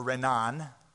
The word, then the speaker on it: Renan: And Ernest Renan, or Renan, as it's probably better pronounced, became influenced by the writings of Hegel and Immanuel Kant and started viewing the gospel stories through their perspective.